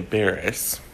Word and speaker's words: Barris: And it's because Kenya Barish, make sure I said his name right, Kenya Barris, who created Blackish and Grownish for ABC.